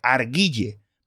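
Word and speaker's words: Argylle: Se escribe Arguille, pero con Y. A-R-G-Y-L-L-E. Es decir, si, yo fuera argentino sería A-R-G-Y-Y.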